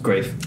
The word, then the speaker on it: Greif: Yeah, I've gone for Max Greif, or Graf, is that how you pronounce it? – Greif. – Greif, yeah. So he's a young chap, German fella, 21 years old, I believe.